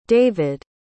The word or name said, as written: davyd